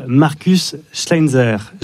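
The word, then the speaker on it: Markus Schleinzer: Le film s'appelle donc La vie d'une femme de Charline Bourgeois-Taquet et votre autre conseil de la semaine, c'est Rose de Marcus Schleinzer.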